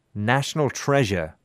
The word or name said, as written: national treasure